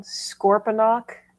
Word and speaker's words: Scorponok: You're going to have to help me with this next one, Scorponok. – Yeah, you said it correctly.